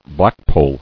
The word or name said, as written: Blackpoll